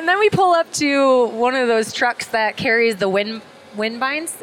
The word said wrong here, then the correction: turbines: Oh, the turbines.